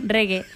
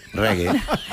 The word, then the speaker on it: reggae: Reggae. – Reggae.